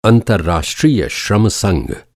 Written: अंतरराष्ट्रीय

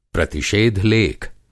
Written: प्रतिषेध-लेख

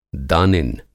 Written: दानिन

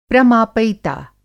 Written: प्रमापयिता

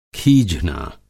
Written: खीझना

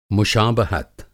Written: मुशाबहत

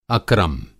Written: अक्रम

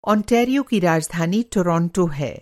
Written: ओंटारियो

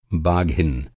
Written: बाघ

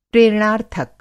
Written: प्रेरणार्थक

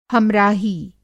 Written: हम-राही

